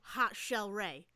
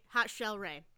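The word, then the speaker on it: Hot Chelle Rae: Hot Shell Ray. And that is spelled HOTCHELLE RAE, right? Hot Shell Ray.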